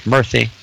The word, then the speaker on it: Murthy: This is his Indian by ethnicity, Vivek, V-I-V-E-K-M-U-R-T-H-Y, pronounced Murthy.